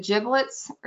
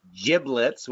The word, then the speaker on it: giblets: Jack and I, both from the northeast, uh, go with the Google approved pronunciation of the word giblets with a j j j. – Giblets. – The south southern folk, Amanda and Kevin, go with the g g g version of that word.